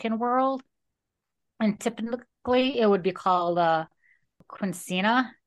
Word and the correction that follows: Quinceañera: and I hope I'm saying that right. – Quinceañera. – Okay, thank you.